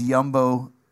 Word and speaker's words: Jumbo: We didn't pronounce the J. It was a Winnipeg thing. So it was Jumbo Video.